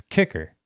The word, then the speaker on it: KIKK: And it says kicker.